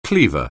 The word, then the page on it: cleaver: anglais